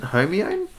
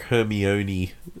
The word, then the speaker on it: Hermione: Isn't it Homione? But yeah, I'd pronounce the E though as well. I'd be like Hermione.